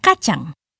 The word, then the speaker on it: kacang: In Malay, we say kacang.